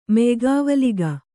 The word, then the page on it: ಮೆಯ್ಗಾವಲಿಗ: kannada